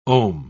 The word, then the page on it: om: British English